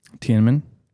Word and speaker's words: Tiananmen: In 1949, the Chinese communists occupy Tiananmen Square. – Is it what? How do you pronounce that? – Tiananmen. – There's no M. Tiananmen Square.